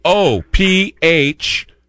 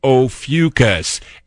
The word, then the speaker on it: Ophiuchus: It's Ophiuchus, Ophiuchus.